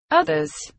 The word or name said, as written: others